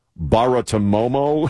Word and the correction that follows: Bartiromo: Romo, Baratomomo. – Bartiromo. – Bartiromo. I always have a problem with that.